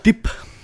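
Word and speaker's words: Dippé: Øh, den er instrueret af Mark øh, DePay. Jeg tror, det er sådan, man udtaler det som Jeg tror, før. man siger dip.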